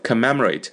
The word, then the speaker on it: commemorate: to commemorate，哎，这个单词 commemorate 表示纪念啊，纪念什么事情啊，什么抗战纪念啦，就用这个单词 commemorate，重音在第二个音节上 m 上面啊，不要念成 commemorate，这不好听的，是 commemorate， commemorate 表示纪念。